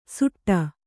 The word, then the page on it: ಸುಟ್ಟ: suṭṭa